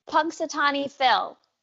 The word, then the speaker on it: Punxsutawney Phil: Punxsutawney Phil.